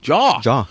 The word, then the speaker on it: Zhao: Yeah, it's not Zhao. – Zhao is what I say. Jaw. – Chloe Zhao. That's how I think that's Jaw. How I pronounce it.